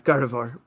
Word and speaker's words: Gardevoir: Who's the other Radiant Pokemon that wasn't Garnita? I said her name wrong. My God. – Gardevoir? – Yeah, sorry, Gardevoir. Well, who's Steelix, the other one? – Steelix and Sneasler.